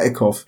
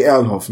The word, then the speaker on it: Erlhoff: weil ich den Namen immer falsch ausspreche, Kari Erlhoff ja. – oder Eckhoff? Erlhoff. Nee, Erlhoff, ne? Ja,